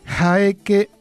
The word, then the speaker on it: Jaeke: Jaeke y Miboli. Jaeke, porque es una J aspirada, empieza con H, pero lo van a pronunciar como J aspirada.